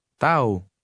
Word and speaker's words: tahu: Tau. Tau. – An H between two vowels is silent.